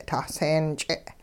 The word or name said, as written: taseen-ch'e